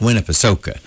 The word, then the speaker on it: Winnipesaukee: It was Lake Winnipesaukee, and you announced the whole thing, pronounced the whole thing like it's supposed to be. No hyphenated stuff, no abbreviated stuff, just Lake Winnipesaukee.